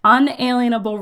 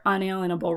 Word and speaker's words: unalienable: So essentially what that means, and I'm going to break this down a little bit, because really all this hinges on is our unalienable rights, which we pronounce as inalienable.